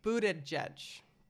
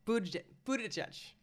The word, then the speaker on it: Buttigieg: Buttigieg. Buttigieg.